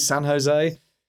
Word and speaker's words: San Jose: Is that how you pronounce it, San Jose? It is.